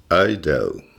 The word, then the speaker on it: Idaho: ça s'écrit, vous connaissez, I-D-A-H-O. Ça se prononce Idaho c'est la Voilà,